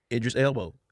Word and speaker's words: Idris Elba: You don't even know how to say his name. – Idris Elba. – Idris.